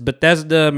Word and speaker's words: Bethesda: I thought it was like Batista, but it's Bethesda, Maryland.